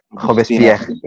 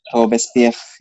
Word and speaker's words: Robespierre: Itu bacanya gimana? Ya, Robespierre. – Robespierre gitu.